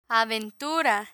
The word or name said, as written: aventura